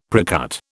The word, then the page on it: precut: British English